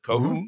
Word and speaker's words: Cahoone: But you go into a really deep dive of Kohunian, is that how you pronounce it? Cahoone? Mm-hmm. I believe so.